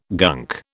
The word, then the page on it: gunk: British English